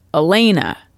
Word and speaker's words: Alana: All of a sudden, now I'm thinking maybe it's spelled A-L-A-N-A, but it's pronounced Elena.